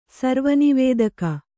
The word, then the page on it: ಸರ್ವನಿವೇದಕ: kannada